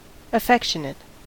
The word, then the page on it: affectionate: American English